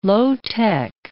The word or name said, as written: low-tech